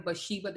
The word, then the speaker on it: Basheba: On Facebook, I am Basheba, that's spelled B-A-S-H-E-B-A, Maiden, like your maiden name, M-A-I-D-E-N.